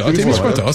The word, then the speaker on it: Témiscouata: C'est Témiscouata, c'est ça? – Témiscouata, c'est bon. Exact.